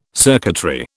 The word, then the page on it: circuitry: British English